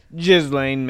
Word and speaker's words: Ghislaine: I completely thought I butchered it, but Ghislaine Maxwell.